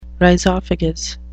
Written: rhizophagous

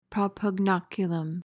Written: propugnaculum

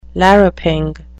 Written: larruping

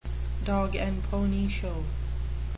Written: dog-and-pony show

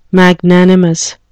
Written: magnanimous

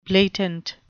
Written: blatant

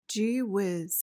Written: gee-whiz

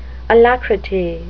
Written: alacrity

